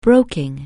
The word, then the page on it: broking: anglais